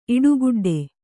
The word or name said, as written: ಇಡುಗುಡ್ಡೆ